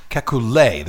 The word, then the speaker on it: Kekulé: Kekulé, there's an accent on the end of it.